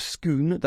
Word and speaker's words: Scone: Now, for the record, it's called the Stone of Scone, that's how you pronounce it, but it's spelled S-C-O-N-E, so like scone or scone, depending on where you're from. But that's how you pronounce it in Scotland in relation to the location.